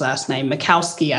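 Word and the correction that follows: Michalowicz: I think. – Mikalowicz. – But if anyone, okay, Mikalowicz, see, I had it wrong.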